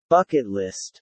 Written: bucket list

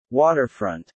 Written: waterfront

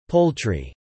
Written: poultry

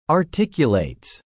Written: articulates

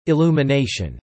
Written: illumination

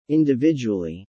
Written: individually

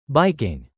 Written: biking

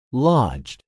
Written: lodged

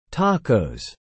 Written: tacos

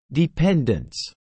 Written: dependents

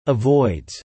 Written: avoids

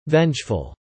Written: vengeful

